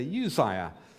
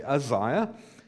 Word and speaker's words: Uzziah: I know when I was brought up, we used to say Uzziah, but I suspect phonetically, we probably ought to say Uzziah.